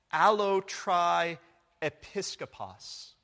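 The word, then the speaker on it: Allotriepiskopos (ἀλλοτριεπίσκοπος): I'll pronounce the Greek word for you as best I can. Allotriepiskopos. Allotri, that's the first part. Episkopos is the second part.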